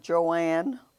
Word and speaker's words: Joanne: I'm Joanne Woodson Thompson, J-O-A-N-N, all together, no capital A, Woodson, W-O-O-D-S-O-N, Thompson, T-H-O-M-P-S-O-N.